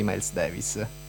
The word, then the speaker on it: Miles Davis: Miles Davis.